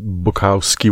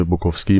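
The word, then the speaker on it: Bukowski: کسی که ما به اسم چارلز بوکوفسکی میشناسیم، تلفظ درست اسمش بوکاوسکی باید باشه، اما از اونجایی که جا افتاده تو فارسی به اسم بوکوفسکی، من هم همین رو تکرار میکنم.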